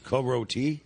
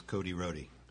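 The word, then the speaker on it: Côte-Rôtie: Often mispronounced as Cody Rhodey.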